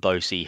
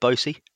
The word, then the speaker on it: Bose: How are we saying it, Bosey? – It's Bose. I don't know where Bose has come from. – That's yeah, I think that's how Keith Cole pronounced it, Bose.